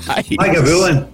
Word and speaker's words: Lagavulin: How do you pronounce Lagavulin? Because we've heard so many different ways. – Lagavulin. The way you're saying it's the way that I would probably say it, yeah.